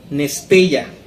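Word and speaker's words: Nestella (νηστεία): Y cuando se escribe ahora la palabra ayuno, se escribe o se lee como nesteia, N-E-S-T-E-I-A. N-E-S-T-E-I-A. Nestella.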